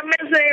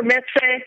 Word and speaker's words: mezze: Is that how you say it? Mezze? – Yeah, mezze or mezze. – Okay. – You know, believe they're all okay, I think.